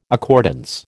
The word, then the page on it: accordance: American English